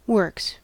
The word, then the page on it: works: American English